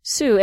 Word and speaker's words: Sault: I was saying Sault Saint Marie because, I don't know, I took French and it seemed like that would be the pronunciation, but yeah. – It's not. – Huh. – Sioux, and then actually they abbreviate everything S-O-O so that people like us pronounce it Sioux.